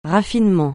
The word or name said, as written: raffinement